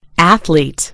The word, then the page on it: athlete: British English